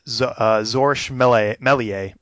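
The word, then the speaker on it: Georges Méliès: I think you'd pronounce it Zorsch Melier, who's George Melier. – Yeah, Melier, Zorsch Melier, played by Ben Kingsley, who does a great job.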